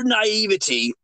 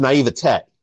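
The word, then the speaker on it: naiveté: Your naivety never ceases to surprise me. – Well, it's actually pronounced naivete, but go ahead.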